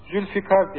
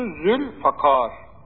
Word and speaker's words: Zülfakar: Zülfikar deniliyor, Zülfikar deniliyor. Hatta kefmiş gibi yanlış. Doğru telaffuzu Zülfakar. Yani fi değil, F harfinden sonra i gelmiyor, a geliyor. Zülfakar. Kefle değil, kaf harfiyle. Yani kar tarzına değil, kar. Zülfakar.